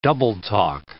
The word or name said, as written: double-talk